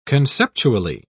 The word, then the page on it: conceptually: British English